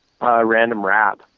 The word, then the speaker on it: Random Rab: You're going out on tour with, is it Random Rob? Is that how you say it? – Random Rab. – Rab. – R-A-B.